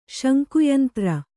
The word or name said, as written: ಶಂಕುಯಂತ್ರ